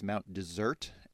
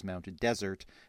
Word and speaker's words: Mount Desert: One of the questions was about some lighthouses in Maine, and I pronounced the name of one of those lighthouses as Mount Desert, and the other way you can pronounce that, as many people in Maine do, is Mount Desert.